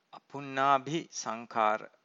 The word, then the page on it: apunnabhi sankhara: Pali